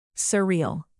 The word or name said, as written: surreal